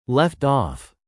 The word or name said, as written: left off